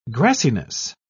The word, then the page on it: grassiness: British English